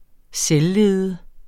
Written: selvlede

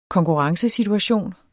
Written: konkurrencesituation

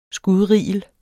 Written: skudrigel

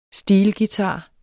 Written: steelguitar

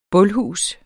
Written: bulhus

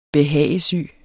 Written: behagesyge

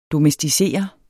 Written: DOMESTICERE

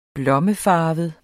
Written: blommefarvet

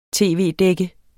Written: tv-dække

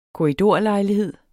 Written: korridor

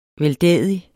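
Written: VELDÆDIGE

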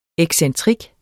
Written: excentrik

